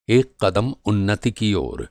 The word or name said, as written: उन्नति